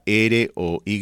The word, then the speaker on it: Roy: Su apellido en realidad se deletrea R o Y, pero se pronuncia Roy, porque él era de la provincia de Quebec, ¿no? Allá en Canadá tiene origen francés.